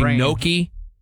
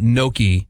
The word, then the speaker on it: gnocchi: People say gnocchi. – Oh. – It's not gnocchi. – Yeah. – Gnocchi. It's like gnocchi. – That's what the G is for. – Come over. – Gnocchi. – Say it correctly one more time. – Gnocchi. – Gnocchi. – Gnocchi. – It's closer than gnocchi.